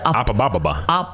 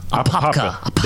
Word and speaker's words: Apopka: How do you pronounce it? – Apababa. – Apopka.